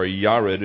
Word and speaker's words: Yared: Jared, or Yared is the way it would be pronounced, is a weird name.